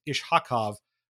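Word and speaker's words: Iskhakov: Ruslan Ishakov was called up. Shout out to Alan Furing for making sure we all say that right. It's Ishakov with a ha in the middle there.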